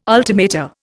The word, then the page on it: ultimata: Indian